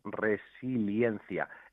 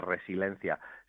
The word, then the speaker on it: resiliencia: Resiliencia o resiliencia. En este caso, lo adecuado es resiliencia. Es cierto que esa combinación vocálica que hay ahí se nos atraganta muy a menudo y por eso se oye con frecuencia esto de resiliencia. Sin embargo, lo, adecuado es eh, que eh, se pronuncie bien ese diptongo eh,